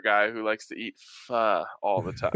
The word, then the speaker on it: pho: No, like pho is the correct way of pronouncing it, right? P-H-O.